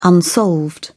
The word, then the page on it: unsolved: British English